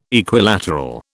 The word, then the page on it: Equilateral: British English